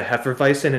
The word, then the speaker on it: Hefeweizen: But by the way, after the episode where I said Hefeweizen instead of Hefeweizen, and Erebus Jones is like, I think you missed a syllable.